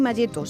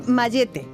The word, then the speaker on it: mayete: Y es curioso y me encanta que en algunos sitios no se dice ni malleta ni malleto, se dice mallete.